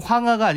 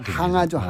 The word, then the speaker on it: 항아: 그러면 황하가 아니라 항아. – 항아죠 항아. 근데 이제 발음상 예, 예, 이제 그렇게 예. 황하로 발음을 했는데 월궁에 있는 항아라는 선녀 이야기잖아요.